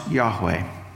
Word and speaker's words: Yahweh: So a name, and I'll begin to use the divine name, which in the Old Testament, it is four consonants, and typically the way it is pronounced is, although there's no way to be certain exactly how it would have been said originally by the Hebrews as God gave his name, but it's typically pronounced Yahweh.